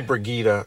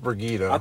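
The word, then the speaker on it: Brigitte: And it's actually Brigida. It's pronounced Brigida.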